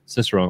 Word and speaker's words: cicerone: Cicerone.